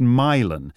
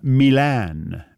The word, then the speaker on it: Milan: He says it's pronounced Milan because he thinks I'm an effete foreign Nancy boy who's going to say Milan, Michigan.